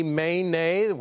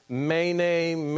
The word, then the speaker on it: Mene: That would be Mene, which would be the pronunciation. Mene, Mene, Tekel, Upharsin.